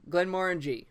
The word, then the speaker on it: Glenmorangie: and he says Glenmorangie rhymes with orange. – There you go. – So there you go. – Sure. – So okay, Glenmorangie Quinta Rubin.